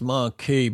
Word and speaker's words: Marquis: It's pronounced Marquee, but it's written Marquis. It's written, Marquis. It's not written Marquee. We would write Marquee, M-A-R-Q-U-E-E, wouldn't we? – We would indeed. – But they do call it the Sunset Marquee, even though it's spelt the Sunset Marquis.